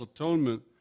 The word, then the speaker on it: atonement: But that transformation from anxiety to reunification with the divine mind, to reunion, to what the Course in Miracles calls atonement, that I pronounce at one-ment, is the goal of all spiritual paths.